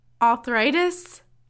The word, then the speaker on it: arthritis: Arthritis? – Arthritis. Like Arthur. Not like author.